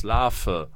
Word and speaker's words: Larve: Spricht man das Larve oder Larve aus? Man spricht es natürlich mit dem Vogel-V aus, Larve.